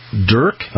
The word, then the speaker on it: DERQ: I'm very honored to have you with us, and you're the Vice President of Business Development at DERQ. I'm saying that right? D-E-R-Q? – Yeah, absolutely.